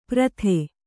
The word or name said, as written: ಪ್ರಥೆ